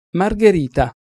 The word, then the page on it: margherita: Italian